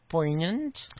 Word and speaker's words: poignant: Can I use the word poignant? – Yes. – Poignant. – Yes. – Okay. Poignant. A little silent G action.